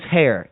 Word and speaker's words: Hair: My last name is Hair, H-A-I-R. – Dude, you are pathetic. Seriously, spell it. – H-A-I-R, Hair.